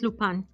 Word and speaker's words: Lupin: Terminé de ver, eh, ¿cómo se pronuncia en francés? ¿Lupin? – Me parece que sí es Lupin. Lupin.